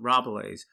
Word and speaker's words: Rabelais: Is that the right way to pronounce it? Rabelais. To match Sotweed Factor's... – That's how they pronounce it in music band, so I would stick with that.